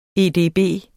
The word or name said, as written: edb